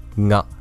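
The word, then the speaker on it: na (が): Alors, avant de commencer, déjà, j'ai dit ga, c'est un peu la prononciation francophone, mais la plupart du temps, les Japonais disent plutôt na.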